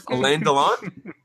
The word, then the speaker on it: Alain Delon: Was that close? Alain Delon? – You nailed it, man. I'm sure our French cinema buffs will appreciate your pronunciation of that.